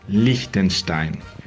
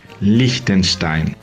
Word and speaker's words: Liechtenstein: La palabra en alemán se pronuncia Liechtenstein. Liechtenstein. Quizás es porque no están eh, acostumbrados con la combinación eh, de las letras que siguen. Em, de un lado tenemos el I y el E que siguen, dos vocales así, y que hacen que el eh, I se pronuncia más lungo, Liechtenstein, Liechtenstein.